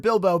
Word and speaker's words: Bilbo: B-I-L-B-O correctly. – Bilbo. Bilbo. – Yep. – You're as smart as a robot, Dennis. You're as smart as a robot. – Now, of course, I know the word Bilbo because I'm a nerd and I watched the Lord of the Ring movies.